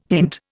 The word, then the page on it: hint: Indian